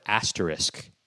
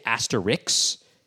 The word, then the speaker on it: asterisk: Oh, so the other thing I wanted to say is people often mispronounce the word asterisk, and that bugs me. They'll say asterix, and I'm just thinking, okay, there's no...